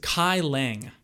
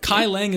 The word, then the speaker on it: Kai Leng: It is Kai Leng. I can spell that for you if you like.